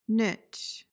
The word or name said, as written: nitch